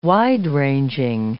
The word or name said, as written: wide-ranging